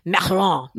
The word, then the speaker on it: macaron: I say macaroon, even though that's really a Passover cookie. – Right. I think of that's the Passover, the coconut cookie. However you pronounce it, she would say macaron.